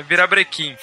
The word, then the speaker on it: virabrequim: É, virabrequim. – Fala direito, né, cara? Cara, eu sempre conheci como vira vira Brecken, mano. Então tô falando errado a vida inteira.